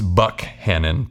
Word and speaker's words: Buckhannon: First, it's Buckhannon, pronounced with a hard K, not to be confused with Buchanan, a much different city in a much different state.